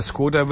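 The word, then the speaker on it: Škoda: Da ist ja ein kleiner Haken auf dem S. Deswegen, der Skoda wird ja eigentlich Skoda ausgesprochen.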